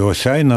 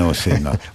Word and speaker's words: Осяйна: Все ж таки Осяйна, а не Осіна. – Осяйна, так. Вона тут, наголос тут. Осяйною зробили такий експеримент.